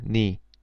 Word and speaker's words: 네: For example, 나의 becomes 내, 너의 becomes 내, but pronounced 니.